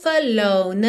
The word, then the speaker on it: alone: Já juntando, porque alone, embora termine na letra E, a letra E não é pronunciada, então o último som pronunciado dessa palavra é uma consoante, letra N, naturalmente já liga na próxima vogal, a letra A de again, ok?